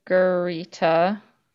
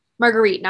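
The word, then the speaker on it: Margherita: Margarita. – Marguerite, not Margarita.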